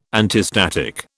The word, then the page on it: antistatic: British English